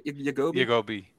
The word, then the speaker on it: Yagobi: Yagobi. – Yep. – Yagobi.